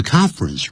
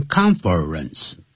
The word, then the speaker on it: conference: conference room 记住不要念成 conference 啊这样要念成两个音节 conference 那个 confer 那个 er 啊不念出来